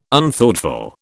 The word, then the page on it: Unthoughtful: British English